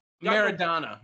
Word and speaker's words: Maradona: Did I say his name right? – Maradona. – Thank you.